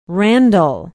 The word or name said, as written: randall